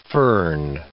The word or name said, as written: fern